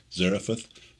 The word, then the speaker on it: Zarephath: Zarephath, son from the dead.